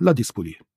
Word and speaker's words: Ladispoli: Die Gemeinde Ladispoli, oder spricht man's? Doch, Ladispoli.